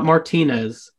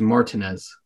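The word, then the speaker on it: Martínez: It is not Martinez. It is Martinez.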